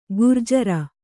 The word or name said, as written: ಘೂರ್ಜರಿ